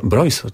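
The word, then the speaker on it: Breus: To jest poradnik, ale myślę, że bardzo ciekawy i sądzę, że myślę, że warto by było wiedzieć o tym, o czym pisze Michael Broyce, tak się wymawia, to jest Amerykanin.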